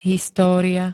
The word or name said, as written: história